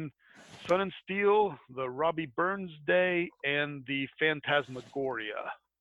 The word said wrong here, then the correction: Phantasmagoriana: or Gorina. – Phantasmagoriana. – Yeah, that's the one.